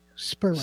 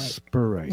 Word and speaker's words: Spurrite: The name of this stone is Spurite, or it's also called Strombolite. – You better spell that. – S-P-U-R-R-I-T-E. Spurite. – Spurite.